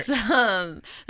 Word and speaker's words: opossum: Possum. – Look at my paper right here. – It's spelled. – Opossum. – Yes, but the O is silent.